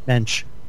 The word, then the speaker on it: Mensch: Louise Mensch, is it? – Mensch.